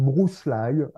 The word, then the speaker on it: Bruce Lee: Ça se prononce Bruce Lee à l'américaine.